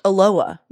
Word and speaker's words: Aloha: This one email I received from Becca Lee this week, it very sweet, was like, good morning, aloha is pronounced aloha, no H sound. Did you guys know this? It's aloha, Oregon, not aloha, Oregon.